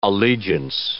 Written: allegiance

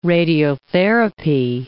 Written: radiotherapy

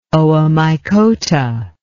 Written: oomycota

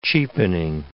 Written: cheapening

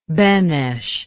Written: Benes

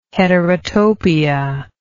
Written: heterotopia

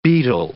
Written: betel

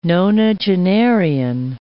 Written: nonagenarian

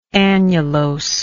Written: annulose

